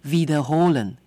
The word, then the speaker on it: wiederholen: 好，所以你需要跟德国人说 Wiederholen Sie bitte。好，因为重复咯 repeat 哦，英文是这样，德文是 wiederholen，中文在后面咯，这个动词有点长 wiederholen。 OK，它是一个 V，也就是英文字母 W 开头的，那德语的发音希望你咬住你的嘴唇 wiederholen，那它就是重复的意思。